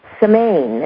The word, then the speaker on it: Samhain: And, you know, the festival that I mentioned, and I mispronounced it, Samhain is the name, the pronunciation of the festival that is Gaelic, and it's the end of the harvest celebration of the harvest season.